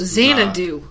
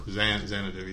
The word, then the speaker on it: Xanadu: Xanadu. – Anytime there's an X in front of an A. It's pronounced Xanadu. – Xanadu, yeah.